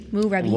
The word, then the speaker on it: Moorabbin: I don't have any idea if I'm pronouncing that right, Moorabbin. – By the way. – It's Moorabbin. – M-O-O-R-A-B-B-I-N. – Moorabbin. – Moorabbin. – Moorabbin. – Moorabbin. – I bet you they say it. – Moorabbin.